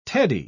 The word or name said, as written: teddy